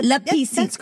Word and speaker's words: la piscine: La piscine? – Yeah, but... – La piscine.